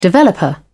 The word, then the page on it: developer: British English